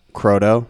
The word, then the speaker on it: Croteau: Croteau.